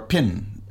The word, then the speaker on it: phin: This is a fin. Or a pin. It's pronounced Thai, kind of like a pen, but it's spelled P-H-I-N.